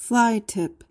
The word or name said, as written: fly-tip